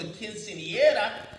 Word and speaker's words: quinceañera: quinceañera.